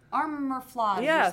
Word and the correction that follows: Aromaflage: or something. How do you say it? – Aromaflage. I feel like I'm going to break it.